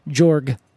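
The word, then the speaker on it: Georg: Oh, yeah, I believe it's pronounced Jorg.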